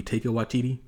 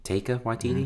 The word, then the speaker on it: Taika Waititi: Taika Waititi, did I say his name right? – I think it's technically maybe Taika Waititi. – Taika Waititi? Mm-hmm. Thank you.